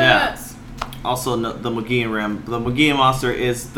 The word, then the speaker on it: Mogollon: because I was looking at it and I said the Mogollon monster, but it's actually the Mogollon, based out of the Mogollon Rim, which is, like, this foresty area.